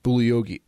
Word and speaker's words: Bugliosi: It was written by Vincent Bugliosi. G is silent.